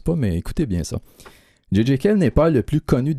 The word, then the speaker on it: J.J. Cale: Un album posthume pour J.J. Cale. En français, JJ, mais en anglais, on prononce J.J. Cale.